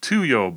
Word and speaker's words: Tuyo: And we had you select a book for us, and we're going to talk about Tuyo by Rachel Neumeier. Is that how everybody pronounces that title? – That's how I pronounce it.